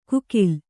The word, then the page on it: ಕುಕಿಲ್: kukil